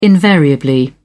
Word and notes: invariably: British English

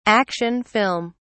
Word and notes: action film: British English

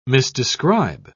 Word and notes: misdescribe: British English

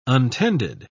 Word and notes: untended: British English

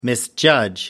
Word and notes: misjudge: American English

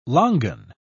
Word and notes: longan: British English

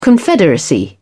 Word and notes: confederacy: British English

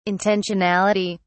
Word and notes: intentionality: British English

